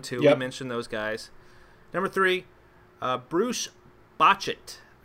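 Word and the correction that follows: Bochte: I don't remember. – Bochti. – I'm not pronouncing it. Bochti, that's right, Bochti.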